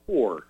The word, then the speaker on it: Hor: His name was, it was spelled H-O-R, pronounced whore.